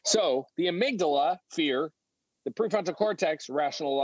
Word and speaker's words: amygdala: In the very back of your brain, near your brain stem, is a little almond-shaped and sized part called the amygdala. That's for those of you who care. That's spelled A-M-Y-G-D-A-L-A.